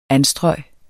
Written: anstrøg

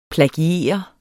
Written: plagiere

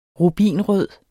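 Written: RUBINRØD

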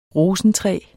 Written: rosentræ

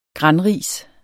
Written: granris

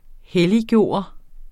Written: helliggøre

